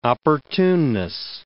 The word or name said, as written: opportuneness